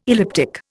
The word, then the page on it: elliptic: Indian